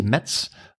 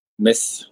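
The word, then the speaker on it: Metz: wij FC Mets vorige week hebben uitgesproken als FC Mets. Maar hoe zou dat correct uitgesproken moeten worden? – Mes. Mes zonder, zonder de T.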